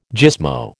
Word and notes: gismo: American English

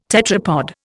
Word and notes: tetrapod: Indian

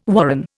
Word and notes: warren: Indian

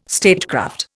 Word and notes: statecraft: Indian

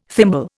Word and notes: thimble: Indian

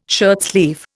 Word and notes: shirtsleeve: Indian

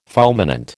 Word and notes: fulminant: American English

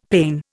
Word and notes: pane: Indian

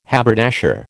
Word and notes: haberdasher: American English

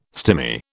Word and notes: stimy: British English

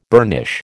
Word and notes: burnish: American English

